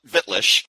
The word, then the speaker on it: Wittlich: You all know me as Wittlich. People call me Wit for short, but it's actually pronounced with a V because it's a German word, a German town that I adopted, if you will.